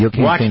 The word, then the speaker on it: Joaquin: At number 10, we have Brad Pitt and Joaquin. Wait, how do you pronounce his name? – Joaquin Phoenix.